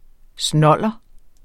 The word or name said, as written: snolder